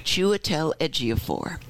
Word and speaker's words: Chiwetel Ejiofor: Chiwetel Ejiofor.